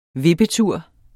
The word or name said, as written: vippetur